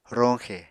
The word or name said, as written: roñxe